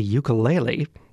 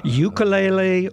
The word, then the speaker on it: ukulele: Ukulele, of course, comes from the original Hawaiian pronunciation, but ukulele has been in common practice in the United States, at the very least, for a long time.